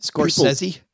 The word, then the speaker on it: Scorsese: I think you said it wrong. I think Scorsese? – Yeah. True film aficionados say Scorsese.